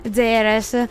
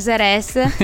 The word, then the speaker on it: Ceres: Allora, si scrive Ceres, solo che abbiamo guardato e in piemontese si dice Ceres praticamente, però in italiano si può dire anche Cere.